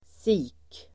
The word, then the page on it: sik: [si:k]